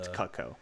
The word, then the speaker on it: Cuoco: Kaylee Cuoco, or I think that's how you say her name. She's great. I'm glad that she kind of broke out of the Cuoco.